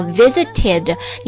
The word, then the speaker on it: visited: 比如说看望、探望啊， visit 加了 ed 之后它读作 visited，你就不能说诶这个 t 它不是清辅音吗，为什么不发 t 音呢、啊、不对啊，当这个原单词以 t 和 d 结尾，然后它加了这个 id 之后呢，它就发作 id id。